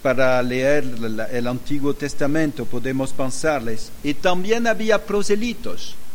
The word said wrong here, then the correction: prosélitos: Prosélitos. a prosélitos, sí. Sí, pronunciación de español y no del francés.